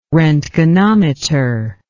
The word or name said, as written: roentgenometer